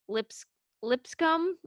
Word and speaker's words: Lipscomb: They face Lipscomb.